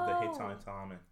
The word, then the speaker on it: Hittite: And I watched a short YouTube video of the guy going, Hittite. It's like 15 seconds, Hittite, three times.